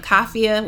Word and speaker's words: Kafia: so it's uh, my first and last name, Kafia, which is spelled like mafia but with a K.